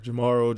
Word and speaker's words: Jemarl: I do want Lobo fans to know when they see the spelling of your name, it is Jemarl. – Yes. – As a Jeff with a G that gets mispronounced all the time, I understand sometimes that people don't want it to be just the most common pronunciation, but it is just Jemarl. Do you get some weird pronunciations of it sometimes? – For sure.